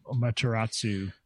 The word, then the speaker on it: Amaterasu: Amaterasu.